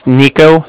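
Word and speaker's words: Niko: It's Nico. That's N-I-K-O.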